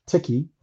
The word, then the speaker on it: Tichi: But I named it Tiki, T-I-C-H-I, and it's pixelated. So if you spell the word T-E-C-H, you come up with tech. So you still pronounce it Tiki.